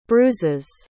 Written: bruises

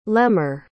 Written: lehmer

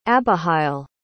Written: abihail

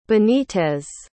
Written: benitez

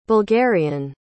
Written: bulgarian

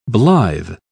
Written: blithe

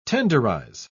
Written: tenderize

